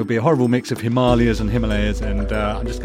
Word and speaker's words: Himalayas: Well, you say the Himalayas, but apparently locally it is pronounced Himalayas.